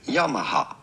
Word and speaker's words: Yamaha: Pronouncing it in Japanese, it would be said Yamaha. Yamaha. – Yamaha. – Yamaha. – Yamaha. – Yamaha. Yamaha. – Ja, nee, noch nie, nicht noch mehr Videos, danke. – Yamaha.